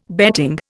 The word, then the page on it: betting: Indian